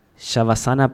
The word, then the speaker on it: Shavasana: And for example, there is one that you talk about the benefits of Shavasana pose. Did I pronounce it correctly? – Mm-hmm.